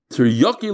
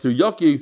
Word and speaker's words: teriyaki: B'chi teriyaki legufa, and it's like teriyaki for the body. In English, it's rayaka. What? How do you say it? Teriyaki. Why did I say rayaka? Teriyaki.